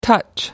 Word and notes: touch: American English

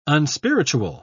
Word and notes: unspiritual: British English

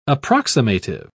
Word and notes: approximative: British English

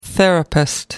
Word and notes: therapist: American English

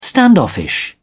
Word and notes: standoffish: British English

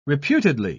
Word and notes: reputedly: British English

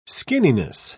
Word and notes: skinniness: British English

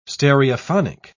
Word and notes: stereophonic: British English

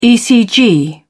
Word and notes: ecg: British English

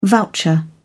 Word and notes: voucher: British English

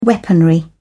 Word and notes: weaponry: British English

